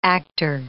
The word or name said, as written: actor